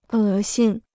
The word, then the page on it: 恶心: è xīn